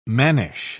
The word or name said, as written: mannish